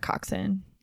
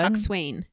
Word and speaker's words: coxswain: Coxswain. But oh, it's not. It's coxswain. It's a coxswain. It has a few extra letters in there, which I do not pronounce.